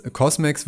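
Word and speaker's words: CosMc's: Cosmex wird geschrieben COS und dann MC, großes M, also wie das McDonalds MC. Und das ist ein Wortspiel, was ich ganz clever finde, weil Cosmex wird ausgesprochen und das steht, soll so ein bisschen auf das Wort Kosmos hindeuten.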